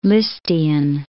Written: Lisztian

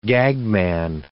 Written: gagman

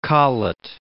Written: Colet

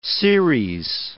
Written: series

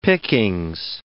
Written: pickings

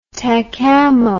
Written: tacamo

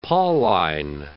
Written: Pauline

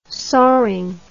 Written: soring

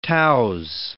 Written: touse

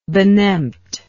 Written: benempt

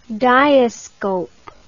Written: diascope

